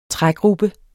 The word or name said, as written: trægruppe